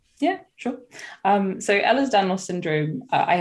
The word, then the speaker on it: Ehlers-Danlos: So as you mentioned, you've been living with joint issues and you've talked a lot on Instagram about living with Ehlers-Danlos syndrome.